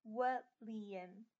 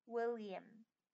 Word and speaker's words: William: William 呢，要怎么念好？William，要记得那个 Liam 的那个 m 要发出来。 William，最主要是那个 m 的感觉。